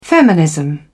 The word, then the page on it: feminism: British English